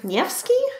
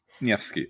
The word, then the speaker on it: Nevsky: Alexander Nevsky? – Nevsky.